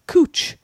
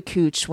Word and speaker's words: Couch: I was in Portland, and they have a street called Cooch, but it's spelled couch. – Couch, yeah. – And why you would go to Cooch when it's spelled couch, that's...